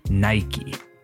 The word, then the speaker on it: Nike: So in the United States and Canada, people say Nike. In the UK, you'll find that people say Nike. In Spain, decimos Nike también. The company calls itself Nike, so I guess if you want a definitive answer, that would be it.